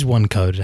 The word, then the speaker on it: OneQode: What is OneCode? How is it spelt? – It's spelt O-N-E-Q-O-D-E. Nice. So OneCode.